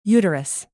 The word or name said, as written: uterus